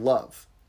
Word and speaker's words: leibh: In Irish, it's spelled L-E-I-B-H, but it's pronounced just like the English word love.